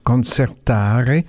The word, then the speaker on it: concertare: Alors, l'origine du terme, c'est un mot italien qui se prononce concertare et qui essentiellement englobe l'idée de rivaliser, de se quereller, de se coltailler.